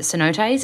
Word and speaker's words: cenotes: Is it cenotes? Is that how you say it? The underwater caves? – Yeah.